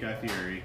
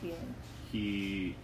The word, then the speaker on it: Fieri: Yeah, so I got to meet Guy Fieri. – Yes, thank you, Fieri.